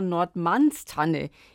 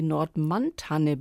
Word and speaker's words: Nordmannstanne: Und in der Botanik spricht man dann immer von Nordmannstanne, also die Tanne vom Herrn Nordmann, die der Herr Nordmann entdeckt hat. Deswegen immer dieses S.